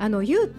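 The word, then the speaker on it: 柚: ゆうってね、ゆず、木片のよしって書く、ゆずっていう字書くんですが、ゆうと読みます。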